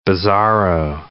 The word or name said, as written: bizarro